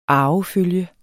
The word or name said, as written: arvefølge